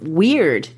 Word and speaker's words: Wyrd: Yeah, because the word he uses for fate there is this Anglo-Saxon term, Wyrd, W-Y-R-D. This is another one of those cultural words that's worth taking a moment to think about. It sounds like the word weird, W-E-I-R-D.